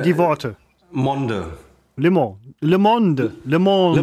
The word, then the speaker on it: Le Monde: Man sagt übrigens Le und nicht... Le. Le. – Und die Franzosen sagen immer, ich kann kein Ö aussprechen, wo ich immer sage, sage Le Monde. Le. Le Monde.